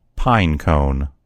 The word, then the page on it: pine cone: American English